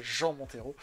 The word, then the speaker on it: Jean Montero: On le prononce en bon français, Jean Montero.